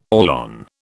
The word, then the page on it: Orlon: British English